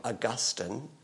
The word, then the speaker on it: Augustine: 354 to 430 were the years of a fellow named Augustine, or you can pronounce his name Augustine.